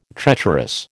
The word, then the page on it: treacherous: American English